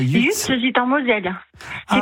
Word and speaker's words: Yutz: Ah d'accord, non, j'avais dit peut-être que c'était en Belgique, mais ça s'écrit comment? – J'ai compris, ça s'écrit UTZ? – Alors c'est YUTZ.